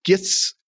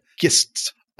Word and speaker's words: Gist: One of the key things that they've added to it is you, if you do gits, gits, is that how you say it? Gist, um, G I S T.